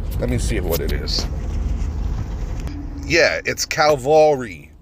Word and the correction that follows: cavalry: Calvary. Let's see. – Cavalry. – Cavalry. See, I'm learning. As I do this podcast, I'm over here learning with you guys. This is how you pronounce it.